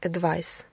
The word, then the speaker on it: advice: Существительное advice, совет, пишется с буквой C и произносится как ice, advice.